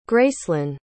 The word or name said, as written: gracelynn